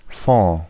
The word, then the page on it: fonds: [fɔ̃]